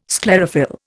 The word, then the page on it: sclerophyll: Indian